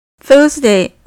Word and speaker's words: Thursday: Thursday、木曜日の Thurs ってなかなか発音しづらいですよね。 th サウンドなので、そういう場合は、舌を噛んで Thurs っていう風に発音するといいと思います。